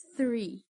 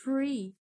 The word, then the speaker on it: three: Só que, diferente de algumas palavras com TH, se você reparar, o the também tem um leve som de Z the the Um erro muito comum na pronúncia do TH é pronunciar como se fosse F. Tente evitar essa pronúncia, pois pode acabar mudando completamente o significado da palavra. Como, por exemplo, three, que significa três. Se fosse pronunciado com F, ficaria free, que significa livre, ou seja, perdeu totalmente o sentido que o falante queria passar.